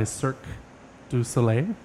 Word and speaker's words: Cirque du Soleil: And then on Sunday is the one that I probably will go to also, is Zoomanity by Cirque du Soleil.